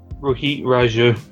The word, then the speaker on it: Rohit Raju: was it Roti, what's his name? – Rohit Raju. – Rohit Raju, yeah. Sorry, I mispronounced that.